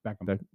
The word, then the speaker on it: Beckham: Is it Beckett or Beckham? – Beckham.